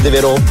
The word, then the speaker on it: Deveraux: quiz mi insegni che Deveraux si legge Deveraux. – Sì, in francese sì.